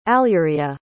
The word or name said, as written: alurea